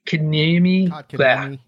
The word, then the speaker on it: Kotkaniemi: He plays center, and Carolina has so many of those that they're already playing Necas, and I can never pronounce this guy's name. Kotkaniemi. – Kotkaniemi, yeah.